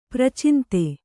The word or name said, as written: ಪರಚಿಂತೆ